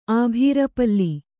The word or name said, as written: ಆಭೀರಪಲ್ಲಿ